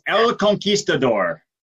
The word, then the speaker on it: El Conquistador: So, tell me, you are known as the El Conquistado. Did I say that right? – Close. You're close. El Conquistador.